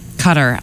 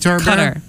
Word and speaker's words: Qatar: Cutter. – I looked up the three different... There's three different ways to pronounce it, but Cutter, you're going with that one? – Qatar.